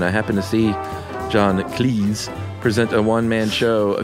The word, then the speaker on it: Cleese: He noted that his name is pronounced to rhyme with cheese rather than fleece. In fact, his surname was originally Cheese, but his father changed it to Cleese.